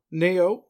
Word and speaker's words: Nioh: Nioh? Is that how you pronounce it? – Nioh, yep.